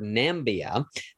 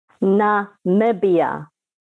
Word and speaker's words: Namibia: Now, again, most Americans are going to say the word Namibia, but actually the country is pronounced. Go ahead and help our listeners understand how to properly pronounce the country. – Namibia.